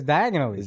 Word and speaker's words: Diagon Alley: Why do you have to pronounce Diagon Alley exactly? – Because it's diagonally.